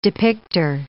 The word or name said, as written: depicter